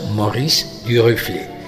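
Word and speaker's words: Maurice Duruflé: que se llama Mauricio Durufle, que es francés, que se pronuncia Maurice Durufle.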